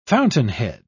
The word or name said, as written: fountainhead